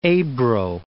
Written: Ebro

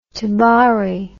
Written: tabari

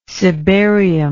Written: cibarium

